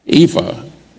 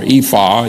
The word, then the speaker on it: Ephah: Ephah is the one that, or Ephah, either way you want to pronounce it, Ephah or Ephah is the other one.